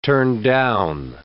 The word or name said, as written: turndown